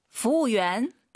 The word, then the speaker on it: 服务员: 服务员。 – 재밌는 건이 저만의 팁을 드리면 후엔 할때그 우를 발음하지 말아보세요. 후엔 해도 괜찮아요. 그죠? – 哦，好像是中国人一样。 – 그죠? 굳이 후엔 이럴 필요 없다고요.